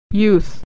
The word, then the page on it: youth: /y/